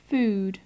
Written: food

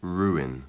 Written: ruin

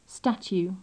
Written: statue

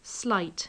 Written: slight

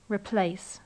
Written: replace

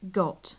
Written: got

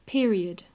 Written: period

